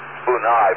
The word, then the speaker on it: Bennu: Bennu, by the way, is B-E-N-N-U. Bennu, I think they pronounce it. B-E-N-N-U, that's the name of the asteroid.